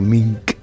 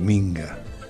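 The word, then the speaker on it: minka: Pero la palabra se llama minca. Como todos nosotros sabemos, nosotros somos corruptos. Entonces, a través del idioma, corrompemos. No podemos pronunciarle tal como es. Por eso que esa palabra ya se ha popularizado por muchos espacios geográficos. Dicen la minga. – Claro. – Es la minca.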